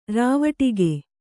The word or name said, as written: ರಾವಟಿಗೆ